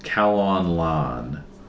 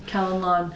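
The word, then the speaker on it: Calon Lân: And the name of the hymn is Calon Lan.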